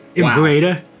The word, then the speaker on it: Imperator: Imperator? – Yes. Imperator would be the tour they were on when we got into this. – You did just pronounce that in correct Latin, though, so you're not wrong.